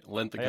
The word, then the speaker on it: Linthicum: David, am I saying that right? – That's right, Linthicum.